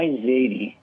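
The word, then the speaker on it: zeyde: Zadie or Zadie? – My Zadie. – Zadie to come home? – Zadie. And you say it with Yiddish inflection, my Zadie.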